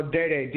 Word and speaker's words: Deday: or you can just spell Dayday, DEDAY.